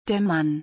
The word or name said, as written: der Mann